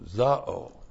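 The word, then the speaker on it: zao (ζάω): And then we have the verb zao. Z-A, long O, zao.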